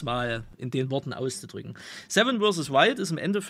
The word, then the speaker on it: Seven vs. Wild: Ja, Seven in the Wilds oder Seven vs. Wild, wie es ja richtig ausgesprochen wird, Seven in the Wilds ist dann eher so Knossi-Speech.